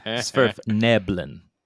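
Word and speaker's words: Svirfneblin: So, I mean, and I'll just say that, like, Sverf Nerblin, is that how you say it? Every time you grimace, every time I say the damn word, say it for me. – Sverf Neblin. – Sverf Neblin? – Yeah.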